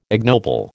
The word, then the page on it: ignoble: American English